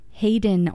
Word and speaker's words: Haydn: Now, his name is spelled H-A-Y-D-N, but it's pronounced Haydn, although he was named for composer Franz Josef Haydn. This is the finale from Haydn Wood's Violin Concerto with Tasmin Little as the soloist.